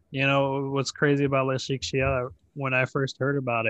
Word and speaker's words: Le Chic Chiot: Ooh, Le Chic Chio. Tell me about it. – It is spelled L-E-C-H-I-C-C-H-I-O-T, and it is a small business for animal accessories.